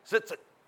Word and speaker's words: tzitzit: It's spelled T-Z-I-T-Z-I-T. Zitzit.